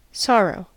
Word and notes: sorrow: American English

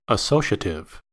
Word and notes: associative: American English

